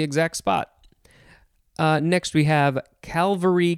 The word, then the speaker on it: Calvary: And I think I said this wrong. Calvary. I think I put another L in there.